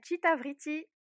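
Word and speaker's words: Cita Vriti: Et donc, est-ce que tu peux préciser le nom de votre blog avec Sineb Oui, ça s'appelle Chita Vriti. Mmh. Alors, ça s'écrit C-I-T-A espace V-R-I-T-I.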